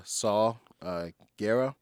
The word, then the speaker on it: Saw Gerrera: Now I guess one of the last characters we'll talk about is Saw Gerrera.